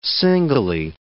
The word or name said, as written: singly